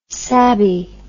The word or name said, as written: sabe